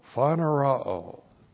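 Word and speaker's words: phanerao (φανερόω): P-H-A-N-E-R-O, long O, phanerao.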